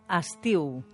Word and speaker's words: estiu: Ah, i ara que ho comentes, es pronuncia estiu. – Estiu, sí. Tot i que en llenguatge col·loquial és molt habitual sentir estiu. – És cert? I per què això? – Hi ha hagut una assimilació vocàlica.